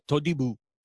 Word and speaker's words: Todibo: Todibo. Is that how you say his name? Todibo? – Oui, je parle français très bien, monsieur. Jean-Claire Todibo.